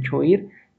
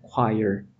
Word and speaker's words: choir: Se escribe choir. Si lo leyéramos tal cual, sería choir, pero se pronuncia choir.